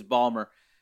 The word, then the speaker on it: Baltimore: Yeah, if you pronounced every letter, yes, that would be the pronunciation, but it's Balmer.